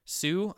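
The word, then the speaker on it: Hsu: And in her place stepped up, what was it, Stephanie Sue? I don't want to mispronounce her name. It's H-S-U.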